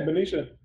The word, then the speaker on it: Benicia: Yeah, Benicia.